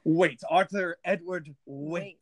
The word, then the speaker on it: Waite: Well, the shot game of today is how often can you mispronounce Arthur Edward Waite? – Waite? – Waite. You're saying Wade. – Wade. Yeah. – Like Wade Barrett or Wade in the water.